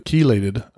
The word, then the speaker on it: chelated: You want it the chelated iron. – Right. – So it's spelled C-H-E-L-A-T-E-D.